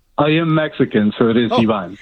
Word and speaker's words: Ivan: This is, okay, it's spelled I-V-A-N, but it's Ivan? Ivan? – It's Ivan. – Ivan. – Ivan. – I'm sorry. Is it Ivan or Ivan? – It's Ivan. – It depends on what language you pronounce. – This guy's already a little sketchy. – Okay, but in English it would be Ivan.